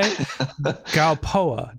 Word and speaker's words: Gralpois: Gralpois.